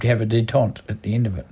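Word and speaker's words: détente: Detente.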